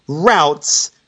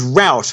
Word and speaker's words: route: he pronounced the word is route, which is acceptable in American English when it should be pronounced to rhyme with shoot in Australian English.